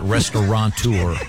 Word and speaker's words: restaurateur: I know that the most mispronounced is restaurateur. – Mm-hmm. – There's no N. It's not restaurateur.